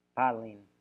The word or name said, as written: palin